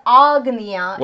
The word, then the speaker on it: Ajna: Yes, think A-J-N-A. – Agnya. – That's the English transliteration. Agnya. But exactly like you said, it's with a long A. – Agnya. – The first A is long. It's Agnya.